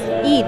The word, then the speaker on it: Eid: Eid. – Eid.